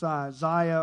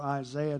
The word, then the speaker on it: Isaiah: It's Isaiah or Isaiah, depending on how you pronounce it, 63, beginning in verse 1.